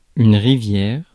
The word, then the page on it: rivière: France (Paris); [yn ʁi.vjɛʁ]; French